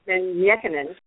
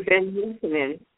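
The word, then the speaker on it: Ben-Jochannan: And everyone, whenever anyone talks about Dr. Yosef Ben Yekanen, even though the last name is spelled Jochannon, I was always sure to make sure that I had the proper pronunciation. So right. – Dr. Ben Yekanen.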